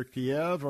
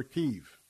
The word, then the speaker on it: Kyiv: either Kiev or Kyiv, depending on the pronunciation. It's known as both.